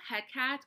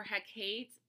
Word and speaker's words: Hecate: Um, also, Hecat or Hecate, another one. I think it is pronounced both ways, but if I have that wrong, let me know.